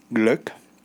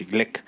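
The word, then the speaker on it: Glück: Y estuve leyendo poemas de Luis, de Luis Gluck, Gleck, que se pronuncia así, Gleck.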